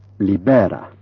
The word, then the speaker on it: libera: Word stress in Esperanto is always on the last syllable but one. So, for example, elefanto, libera. This rule has no exceptions, and some of the following may need care.